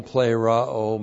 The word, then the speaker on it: pleroo: Now, we have with us pleroo. Pleroo. P-L long E-R-O long O. Pleroo.